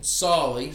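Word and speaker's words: Tsali: Sali. So the T is silent, so it's T S A L I.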